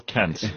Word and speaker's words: Kant: No, his name is Immanuel Kant. Kant. he's German, so it's K-A-N-T, Kant.